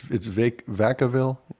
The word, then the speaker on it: Vacaville: It's Vacaville? – Yes. – Am I pronouncing that correct? – Yes.